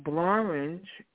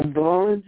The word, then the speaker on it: Blorenge: In a rare example of a word that rhymes with orange, the word blorange is a what? River in Scotland, mountain in Wales, desert in Egypt, forest in Costa Rica. So blorange is spelled B-L-O-R-E-N-G-E.